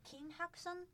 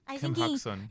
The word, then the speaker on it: Kim Hak-sun: Oh, so Kim Hak-sun. – Kim Hak-sun.